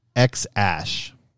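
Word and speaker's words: X Æ A-12: Musk explained to podcast host Joe Rogan that it's pronounced X-Ash.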